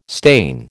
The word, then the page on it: stain: American English